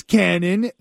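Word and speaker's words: Kannon: So my name is Cannon. That's spelled with a K, and you probably know me because I owe you some money.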